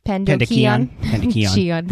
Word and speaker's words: pandocheion: He's using pandocheion. Is that how you'd probably pronounce it? – Pandocheion. – That's probably more Latin, huh? – Pandocheion. – Pandocheion.